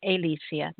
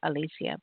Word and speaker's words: Alisia: It's first name Nyla, N-Y-L-A, last name Alisia, A-L-I-S-I-A. And it's actually Alisia, not Alisia, but I always say it wrong, it's Alisia.